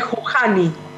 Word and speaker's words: Juhani: no, es, en realidad es Juhani se escribe.